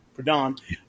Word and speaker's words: Proudhon: I also started reading some Proudhon. However the fuck you say his name. – Proudhon.